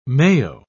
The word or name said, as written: mayo